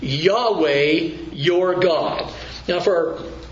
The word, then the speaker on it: Yahweh: Any time in most Bibles now, it's become a convention, that you have Lord in all capital letters, it's telling you that the word itself is most likely pronounced Yahweh. I don't think Jehovah really gets to it, but Yahweh, and that is the personal name of God,